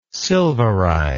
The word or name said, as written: silverize